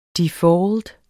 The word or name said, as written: DEFAULT